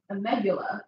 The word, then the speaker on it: amygdala: Studies show that a woman's amygdala, did I say that correctly? – For me, it sounds perfect.